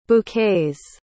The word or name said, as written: bouquets